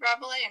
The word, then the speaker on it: Rabelais: I have always said Rabelais and heard Rabelais,